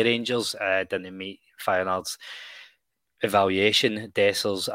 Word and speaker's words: Dessers: It's taken me about 4 days to get his name pronounced right in the news show. I did change it up every day that I said it, but Cyril Dessers and Danilo from Feyenoord, they have been the two main rumours we're hearing.